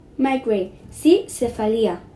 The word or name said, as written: migraine